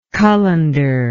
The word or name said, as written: cullender